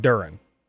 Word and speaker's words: Duren: Because nothing in the English language tells me that D-U-R-E-N is Duran. Why is it not Duran? – Maybe it is. – I mean, it's clearly Duran.